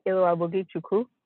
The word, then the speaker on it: Iloabugichukwu: Yes, Iloabugichukwu.